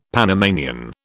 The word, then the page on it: Panamanian: British English